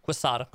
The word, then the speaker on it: Quesada: Quesada.